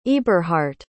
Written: eburhardt

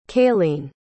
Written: cayleen

